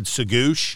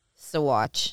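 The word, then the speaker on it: Saguache: I think I said Sagoosh. And what's the real pronunciation? – Sawatch.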